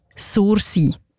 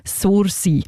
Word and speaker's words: Sursee: Einmal so aussprechen, wie ein Einheimischer oder ein Einheimische das würde. Und ich komme ja selber auch von dort und zeige es euch jetzt schnell mal vor. Sursee. Nicht anders, Sursee.